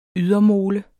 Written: ydermole

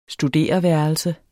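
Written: STUDEREVÆRELSER

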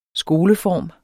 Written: skoleform

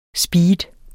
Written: speed

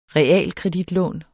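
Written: REALKREDITLÅN

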